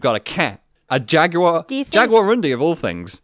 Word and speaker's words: Jaguarundi: So, it's pronounced Jaguarundi.